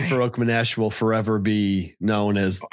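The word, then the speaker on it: Farokhmanesh: Ali Farouk Manesh.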